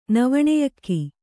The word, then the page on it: ನವಣೆಯಕ್ಕಿ: Kannada